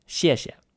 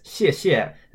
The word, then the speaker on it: 谢谢: That's the same character pronounced 谢 in the fourth tone repeated twice. And just like with mama, the second repetition is pronounced toneless. So to say thank you, we get 谢谢. Fourth tone, no tone.